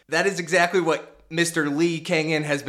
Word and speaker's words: Lee Kang-In: I don't think I need them because have you seen the things that Lee Kang-In has been up to? I think I did that right. – Yeah, correct pronunciation, but I have not seen any news on him.